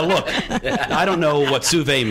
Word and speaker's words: sous vide: Yeah, it's sous vide.